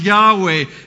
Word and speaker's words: Yahweh: You see, in Hebrew, it's just those four letters, right? Y-H-W-H. It's the name we try to pronounce as Yahweh.